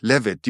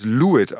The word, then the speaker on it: Levitt: Richtig, Levitt. Die meisten Leute sprechen ihn als Lewitt aus, aber er wird Levitt ausgesprochen.